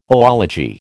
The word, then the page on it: oology: American English